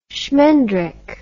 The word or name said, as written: schmendrick